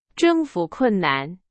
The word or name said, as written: 征服